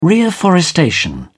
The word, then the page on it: reafforestation: British English